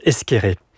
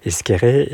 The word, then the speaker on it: Esquerre: Et donc, le premier jour de la classe, généralement, on disait Esquerre et je reprenais le professeur en disant non, ça se prononce Esqueré. – Vous n'avez jamais voulu mettre un accent sur ce nom ? – J'ai voulu garder cet écart, si je puis dire, entre la manière de le prononcer et la manière de le prononcer dans le Nord, parce que dans le Sud-Ouest, on prononce Esqueré, et cet écart entre la manière de le prononcer et la manière de le lire.